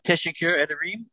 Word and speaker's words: Teşekkür ederim: Teşekkür ederim, and I'll let Jed take it from here. – You know, that was good pronunciation, but let me just say one more thing then.